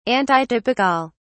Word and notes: antitypical: British English

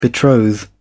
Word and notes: betroth: British English

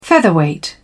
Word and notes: featherweight: British English

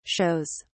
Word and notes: shows: British English

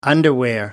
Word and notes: underwear: American English

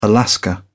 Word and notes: alaska: British English

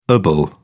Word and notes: ible: British English

